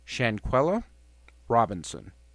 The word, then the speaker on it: Shanquella Robinson: This is in regards, of course, to the horrifying case of, I'm going to try to say her name right here, Shanquella Robinson.